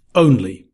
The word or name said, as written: only